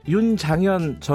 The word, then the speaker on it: 윤장현: 제가 아까 윤장현 전 광주시장을 윤창현이라고 발음을 한 모양이네요.